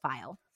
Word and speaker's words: Feil: It's spelled F-E-I-L, but I think it's pronounced File, who was the Whoa.